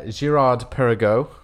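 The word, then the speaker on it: Girard-Perregaux: So I got Girard Perregaux, as I learned how to pronounce today. – Is that how it's pronounced? – That is how it's pronounced.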